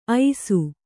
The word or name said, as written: ಐಸು